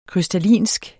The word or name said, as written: krystallinsk